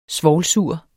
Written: svovlsur

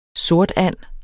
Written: sortand

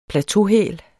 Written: plateauhæl

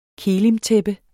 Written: kelimtæppe

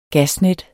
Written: gasnet

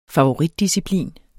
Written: favoritdisciplin

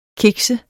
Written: kikse

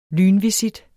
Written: lynvisit

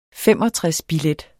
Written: 65-billet

